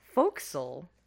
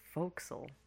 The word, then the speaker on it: forecastle: Because I learned that fo'c'sle is spelled forecastle, but it's pronounced fo'c'sle.